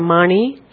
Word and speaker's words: Mani: Mani, en sánscrito se pronuncia Mani, es joya.